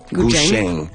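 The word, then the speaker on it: guzheng: Ça se prononce Gusheng. Ouais, Gusheng. C'est plus un CH, un TCH, mettons.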